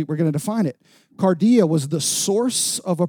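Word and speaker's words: kardia: It's pronounced cardia.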